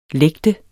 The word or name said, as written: lægte